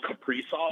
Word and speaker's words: Kaprizov: Just imagine pairing Jack Eichel with Kirill Kaprizov or whatever that is.